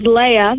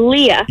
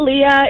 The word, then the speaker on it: Leah: And it always gets on my nerves, but I just politely tell them, oh, it's not Leah, it's Leah, like that. And they're like, oh, okay, I'll try to remember. And so something that always helps them remember that I say is like Princess Leia from Star Wars.